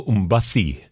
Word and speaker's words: Mbassi: That's the wonderful vocals of Miss Coco Mbassi from Cameroon. Mbassi is spelled M-B-A-S-S-I. Coco Mbassi comes from a brand new album from her called Uwekedi.